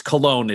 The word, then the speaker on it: Cologne: Also, it's spelled colon. I think they're trying to keep it fancy, but it's Cologne is how they officially pronounce it.